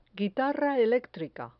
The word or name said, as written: guitarra electrica